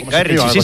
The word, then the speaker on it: Guy Ritchie: Lo he dicho bien, Guy Ritchie. – Sí, Guy Ritchie, sí.